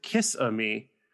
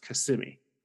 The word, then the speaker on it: Kissimmee: I pronounced it Kiss-a-Mee when I saw it on the sign and was promptly corrected and told it's pronounced Kissimmee.